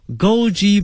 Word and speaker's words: Goji: Goji Berry Goji G-O-J-I 跟我们汉语的发音是差不多的。 Goji Berry G-O-J-I 后面加一个 Berry B-E-R-Y 这个词合到一起呢叫做枸杞 Goji Berry。